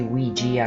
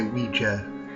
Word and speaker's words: Ouija: Now, the pronunciation of the Ouija board, it can vary. I say Ouija, I grew up calling it Ouija board. – I say Ouija. – Both appear to be correct, so you're going to hear both pronunciations, just go with it.